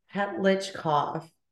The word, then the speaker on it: Petlichkov: Petlichkov. – Thank you. So it looks confusing, but it's actually like phonetically exactly how it's spelled.